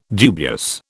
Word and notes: dubious: British English

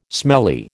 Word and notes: smelly: American English

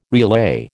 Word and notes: relay: American English

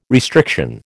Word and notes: restriction: American English

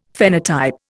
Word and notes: phenotype: Indian

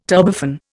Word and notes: turbofan: Indian

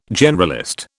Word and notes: generalist: British English

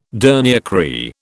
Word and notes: dernier cri: British English

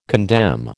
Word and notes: condemn: American English

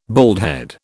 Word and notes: baldhead: British English

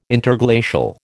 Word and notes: Interglacial: American English

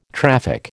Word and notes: traffic: American English

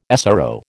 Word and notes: SRO: American English

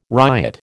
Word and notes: ryot: American English

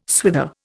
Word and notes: swither: Indian

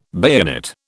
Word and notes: bayonet: British English

